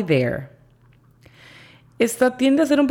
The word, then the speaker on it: there: La pronunciación fonética se escribiría J-A-I para decir hi, y la pronunciación fonética de there sería D-E-R, con la pronunciación correcta, there.